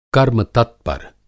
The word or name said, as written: कर्म-तत्पर